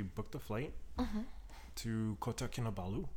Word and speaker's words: Kinabalu: Kinabalu.